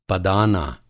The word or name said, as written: पदाना